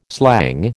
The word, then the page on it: slang: American English